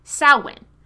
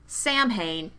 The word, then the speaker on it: Samhain: I was taught to pronounce it like a pig's victory, Samhain. But it's anglicized Celtic, so probably any pronunciation is equally right.